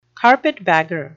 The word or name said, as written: carpetbagger